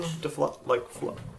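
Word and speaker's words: phlogiston: Is it a soft G in flogiston? – Yeah.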